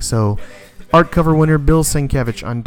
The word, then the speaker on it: Sienkiewicz: And the art cover of the week was Daredevil, or the art cover winner, Daredevil number 6 by Bill Sienkiewicz. Sorry if I pronounced that incorrectly. Sounds like about right. Sienkiewicz. Sienkiewicz.